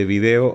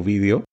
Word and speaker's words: vídeo: Porque los medios, digamos, todo el material original de video o vídeo, como se pronuncia en España, está en la nube.